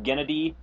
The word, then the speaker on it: Gennady: It's spelled Gennady, but in interviews I've heard it's pronounced like Kennedy, but with a G. – Oh, okay. – So I'm gonna go with Gennady Tartakovsky.